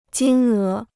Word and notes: 金额: jīn é

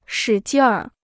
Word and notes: 使劲儿: shǐ jìn ér

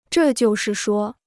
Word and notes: 这就是说: zhè jiù shì shuō

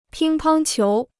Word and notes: 乒乓球: pīng pāng qiú

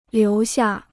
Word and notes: 留下: liú xià

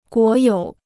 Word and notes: 国有: guó yǒu

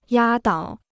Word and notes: 压倒: yā dǎo

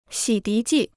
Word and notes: 洗涤剂: xǐ dí jì

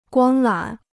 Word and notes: 光缆: guāng lǎn

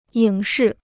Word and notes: 影视: yǐng shì

